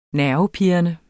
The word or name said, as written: nervepirrende